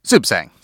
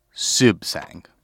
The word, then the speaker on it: Suebsaeng: Sibsang. – Sibsang. – Sibsang. It's very Thai. – Break it down into the syllables for me. – Aswin, last name, Sibsang.